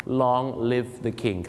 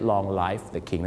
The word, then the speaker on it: long live the king: ออกเสียงกันถูกต้องสักทีนะครับ long live the king ครับผมขอให้ทรงพระเจริญไม่ใช่ long life the king